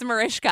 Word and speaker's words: Mariska: It's Marishka. I said it like that. I said it just like that, Marishka.